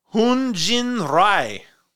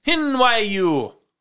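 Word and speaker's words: Hyun-jin Ryu: Pitcher for the Toronto Blue Jays, Hoon Jin Rai. – No. – No? – No. – Hoon Jin Ryu. No? – No. – How do you say it? – Hin Ryu. – Hin? Yeah, okay. Hin? Not Jin? Hin.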